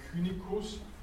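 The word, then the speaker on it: Cynicus: Ja, ich habe schon gesagt, äh, das lateinische C wird als K ausgesprochen, Kynicus.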